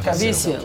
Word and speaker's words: Caviezel: ¿Caviezel se pronuncia? – Caviezel. Caviezel. Caviezel conocido por su interpretación de Jesucristo en La Pasión de Cristo, de Mel Gibson.